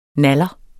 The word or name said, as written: naller